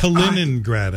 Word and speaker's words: Kaliningrad: It's Kaliningrad, I guess, properly. – Kaliningrad, yeah, that's correct. Yeah, we're both mispronouncing it.